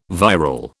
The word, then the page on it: viral: British English